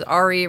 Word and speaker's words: Ari: I replied to Ari to ask for his pronouns, and he also supplied to me that his name is Ari, rhymes with safari, which I really appreciate.